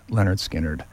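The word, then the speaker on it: Lynyrd Skynyrd: pronounced Leonard Skinner, the one with Freebird on it, and Working for MCA, and I Ain't the One.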